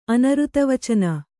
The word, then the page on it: ಅನರ್ಥವಚನ: anarthavacana